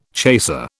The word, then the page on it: chaser: British English